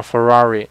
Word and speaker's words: Ferrari: 你就比如说法拉利吧，法拉利按英语的方法应该读成 Ferrari，但是你如果说按意大意大利语读呢，好像是。类似费拉里这样的一个一个一个音吧，反正超级超级难读啊！